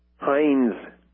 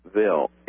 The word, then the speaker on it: Hinesville: And you can stand up with Sergeant Benderman and write to him if you'd like at Post Office Box 2322, Hinesville, Hindesville, H-I-N-D-E-S-V-I-L-L-E, Georgia, 31310. Did I get that right, Kevin? – The Hindesville is spelled H-I-N-E-S-V-I-L-L-E. – Oh, like Heinz ketchup. – Yes.